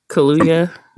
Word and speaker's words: Kaluuya: Like, what's Daniel Kaluuya? How do you say his last name? – Kaluuya.